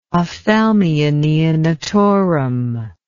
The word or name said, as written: ophthalmia neonatorum